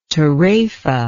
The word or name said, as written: terefah